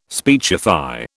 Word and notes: speechify: British English

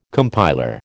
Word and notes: compiler: American English

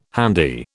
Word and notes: handy: British English

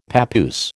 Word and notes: papoose: American English

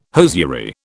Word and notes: hosiery: British English

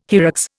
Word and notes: hyrax: Indian

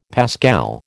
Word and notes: pascal: American English

